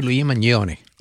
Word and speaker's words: Luigi Mangione: Se pronuncia así, Luigi Mangione.